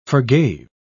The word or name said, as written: forgave